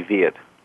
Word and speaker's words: Veit: Fairly close, long E, Veit. – Veit, okay. I had it right for V-E-I-T for listeners who want to look up the books.